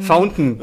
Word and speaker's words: Fountain: Fountain.